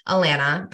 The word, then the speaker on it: Alana: But many people pronounce it Alana or Alana, and I pronounce it like Atlanta without the T's or rhyming with banana.